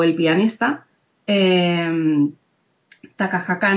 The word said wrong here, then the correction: Takahata: no, ya, he dicho mollón de sílabas. No, lo, lo he dicho bien. Takahaka, Takahata ya se puede llamar James o algo.